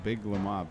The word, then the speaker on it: Big Lemoi: The band is pronounced Big Lemoir, B-I-G-L-E-M-O-I. – All one word.